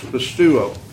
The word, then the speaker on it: pisteuo: In English, it's spelled and pronounced pisteuo, P-I-S-T-E-U-O. If you look that word up in any Greek dictionary, they're all the same.